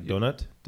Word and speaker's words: Donat: All right, we are here with Hugo Donat. Yeah. Like donut? Donut. – Yeah, like a donut. Like it's pronounced like a little bit differently, donut.